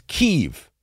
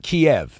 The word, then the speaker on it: Kyiv: And the town he's near is Kyiv, not Kiev.